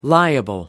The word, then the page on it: liable: American English